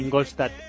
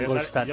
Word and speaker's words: Ingolstadt: Sí, lo he dicho bien. – ¿Lo he dicho bien? – Ingolstadt. – Mira, Ingolstadt. – Ya es un poquito alemán.